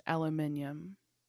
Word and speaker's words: aluminium: And for our British listeners, aluminium. – What? – That's how they pronounce it.